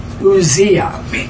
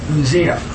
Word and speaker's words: Uzziah: So his name, if you pronounce, is Uzziah. Uzziah. Verse 5.